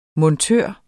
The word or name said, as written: montør